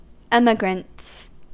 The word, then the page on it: emigrants: American English